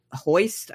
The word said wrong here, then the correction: Holst: I, I think I'm mispronouncing uh, the cinematographer's name? Holst. – Oh yeah. Holst, yeah.